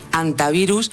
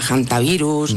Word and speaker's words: hantavirus: No es infrecuente oírla quizá de ambas formas, pero lo adecuado es en efecto dejarla muda, decir hantavirus y no aspirarla, hantavirus, ni nada similar.